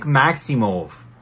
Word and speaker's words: Maximov: Carl Roberson, Nick Maximov, almost like mauve, like the color. That's how he pronounced it on Contender Series. That's how the commentators say it.